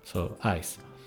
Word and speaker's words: ijs: So ice is ice, pronounced almost the same, written a bit differently, because ice in English, you know, ICA, and ice in Dutch, IJS, but pronounced almost the same. So ice is ice, but a bit of an open because I and J makes the sound called I, so ice.